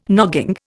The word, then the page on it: nogging: Indian